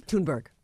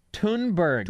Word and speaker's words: Thunberg: How did you say her name? – Thunberg. – Thunberg. – Thunberg. Greta Thunberg. – Thunberg.